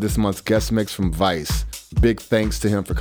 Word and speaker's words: Weiss: Spelled with a W, but it's pronounced Weiss, like Miami Vice.